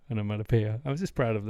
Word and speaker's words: onomatopoeia: You also said that wrong. You said it wrong. – Oh, yeah? – Yeah. We say onomatopoeia.